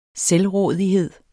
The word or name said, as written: selvrådighed